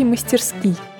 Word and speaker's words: мастерски: В нем ударение падает на первый слог, но это вовсе не значит, что в однокоренном слове «мастерски» оно сохранится. Акцентологической нормой считается ударение именно на последнем слоге.